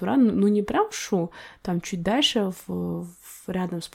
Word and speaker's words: Шу: Вот, кстати, перед этим еще хотела сказать, вот я же с Чу, у нас в Жамбылской области... – Шу, правильно говорить. – Ну да, Шу, да, это раньше так Чу говорили.